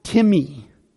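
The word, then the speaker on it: timi (τιμή): The word that is translated as honor, it looks like the word time, but it's pronounced timi, timi.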